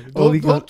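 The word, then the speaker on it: Ole Gunnar: Just say Ollie Gunner. – I just watched Solo yesterday. – It's not Old. It's not Old. It's Ollie. – It's Old Gunner, bro. It's Old Gunner. – Ollie Gunner Solskjaer.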